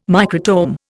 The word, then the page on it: microtome: Indian